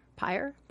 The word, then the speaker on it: pyre: Pyre? – Pyre. – Pyre. pier. – Like a fire. – But yes, pyre.